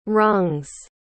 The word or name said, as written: rungs